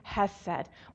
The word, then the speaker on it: hesed: The word for mercy that's used here in the Hebrew scriptures, it's a little word, it's pronounced hesed.